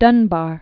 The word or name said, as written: Dunbar, Paul Laurence